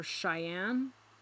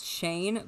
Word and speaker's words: Cheyne: But in 1988, his parents pulled him and his younger brother, Shane, or Cheyenne. – I do think it's Shane, but it's spelled C-H-E-Y-N-E.